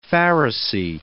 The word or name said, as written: pharisee